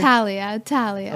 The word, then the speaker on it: Thalia: Thalia.